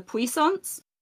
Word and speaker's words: puissance: So like there's one evening where they're like competition for the evening is the puissance, which it's a French name, but it's basically the one with the wall where every round the wall gets higher.